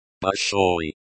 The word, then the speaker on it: Большой: Большой. Большой, pronounced like большой, with the O sound as in go, and the OI sound as in boy.